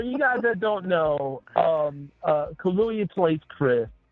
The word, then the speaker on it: Kaluuya: It's like Kaluuya. – That's it. Kaluuya. – Yeah, I said it right. – It's like Kahlua, but yes. With a Y.